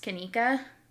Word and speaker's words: Kenneka: Um, and I've only ever heard her her name pronounced Kenneka. So it's K E N N E K A.